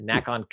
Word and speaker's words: NACON: It's not a long A. The NACON Connect 2023, where they showed more on Robocop Rogue City, which is the one that I'm most interested in.